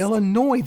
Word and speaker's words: Illinois: No, but you it's not pronounced Illinois. – What's it pronounced? – Illinois. The S is silent.